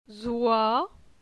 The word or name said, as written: Zoi